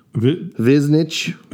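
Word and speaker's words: Višnjić: Viznich. – Yeah, you go there. – Leave out a couple of the letters that are in there. They're silent.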